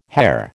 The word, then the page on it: Herr: American English